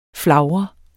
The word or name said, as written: flagre